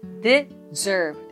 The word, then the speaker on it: deserve: 音節は 2 つ deserve ですアクセントは 2 つ目の E について deserve となります